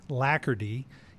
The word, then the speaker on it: alacrity: Our word of the day is one that's interesting because when you look at it, it sounds one way, but it actually is pronounced lackardy, yet it's spelled A-L-A-C-R-I-T-Y,